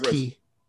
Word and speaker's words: quay: That's pronounced Key. Q-U-A-Y? – Yes, it's pronounced Key.